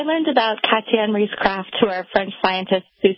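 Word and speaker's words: Krafft: Or is it Kraft? – Kraft. – Okay. – Yes. – A little extra vowel in there.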